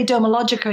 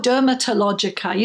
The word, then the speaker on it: Dermalogica: And then, of course, you know, when you realize that people are talking to you, you say Dermalogica and they don't say, oh, Dermatologica, you know, which is a common mispronunciation. I'd say, no, the T is silent, actually.